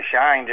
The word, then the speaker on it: Scheine: She was born Margaret Raynor, and so she named me Raynor, and the Scheine just came on my union card. – I see. Very good. It's spelled a little differently, but it is a sort of play on the Rain or Scheine, because you certainly are that kind of actor.